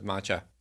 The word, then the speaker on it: matcha: 7 health benefits of matcha. Am I saying that right? Matcha? – Matcha. – Where's the emphasis? – Matcha. – It's matcha. Okay.